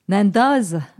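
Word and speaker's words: Nendaz: Nanda, Nandaz. Mais on ne prononce pas le Z. – Mais en fait, on dit Nanda.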